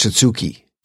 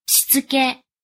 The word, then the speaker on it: Shitsuke: It's an organization method that uses 5 Japanese words that I'm going to mispronounce here. Seri. – Seri. – Seiton. – Seiton. – Seiso. – Seiso. – Seketsu. – Seiketsu. – And Shitsuki. – Shitsuke.